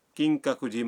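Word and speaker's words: کنگ ککوجی (金閣寺): مثلا آپ کیوتو کے کنگ ککوجی مندر جانا چاہتے ہیں. مندر کے نام کا تلفظ ہے کنگ ککوجی.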